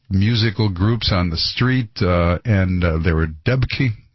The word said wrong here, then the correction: debka: Is that how you say it? Debki line, sort of a dance line. In fact, they said debka. I think that's it.